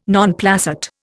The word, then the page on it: non placet: Indian